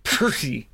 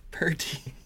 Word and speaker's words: PRTI: It's pronounced Purty. – Purty.